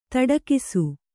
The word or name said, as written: ತೊಡಕಿಸು